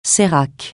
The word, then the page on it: sérac: francais